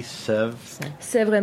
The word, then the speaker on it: Sèvre: a Domaine de la Pépière Clisson Muscadet how do you pronounce S E V R E? Sèvres Maine.